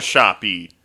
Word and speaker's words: Shoppe: It's spelled S-H-O-P-P-E. – We call it a shoppy.